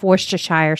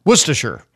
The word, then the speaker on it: Worcestershire: 1 tablespoon of Worcestershire sauce. Did I say that right? – Worcestershire. – Sorry, Worcestershire.